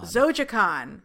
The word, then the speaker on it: Zojaqan: Zojaqan.